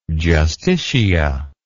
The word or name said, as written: Justitia